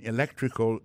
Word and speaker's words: electrical: Accent tombé sur deuxième syllabe ça. Electrical engineering.